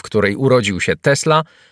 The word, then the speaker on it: Tesla: Nazwisko Tesla, wymawiane z równym akcentem na obie sylaby, w czysto dosłownym znaczeniu jest nazwą zawodu, jak Smith – kowal, Freiter – woźnica lub Carpenter – stolarz.